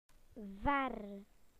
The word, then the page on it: far: British English